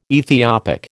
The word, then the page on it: Ethiopic: American English